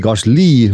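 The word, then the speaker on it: Gasly: Kasli või Kasli, nagu prantsuspäraselt hääldada, oli üheteistkümnes, selle koha pealt väga hea tulemus.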